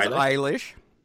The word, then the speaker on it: Eilish: It is Eilish.